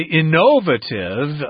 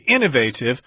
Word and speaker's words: innovative: I've heard some people say innovative. I think I heard a Canadian say it that way once, but Americans say innovative with the accent on the first syllable there. That doesn't mean it's correct, but that's just how we say it.